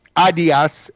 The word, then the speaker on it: Adidas: Adidas, actually, the correct German pronunciation.